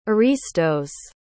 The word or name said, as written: aristos